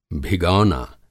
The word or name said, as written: भिगौना